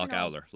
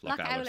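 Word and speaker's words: Lough Ouler: Then there is the Loch Uller Loop, the Loveheart Lake Loop. – I love that one. So Loch Uller. I never Loch Owler. Loch Owler. Sorry, that was my pronunciation.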